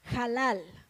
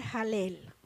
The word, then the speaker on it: Halal: Número tres Halal. O se puede pronunciar halel.